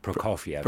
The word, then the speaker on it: Prokofiev: And so if anyone listening has not heard, I'm going to butcher this, Prokofiev, Prokofiev.